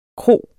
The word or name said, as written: kro